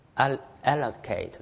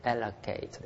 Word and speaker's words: allocate: まあ読み方は特にアメリカでは allocate ですね。ローじゃないですね。 allocate ですね。